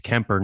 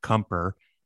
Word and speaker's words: Kemper: If you're going to take Kemper, it's Kemper, not Kemper, for Vezina, at least get his name right, number one.